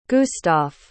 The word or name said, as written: gustaf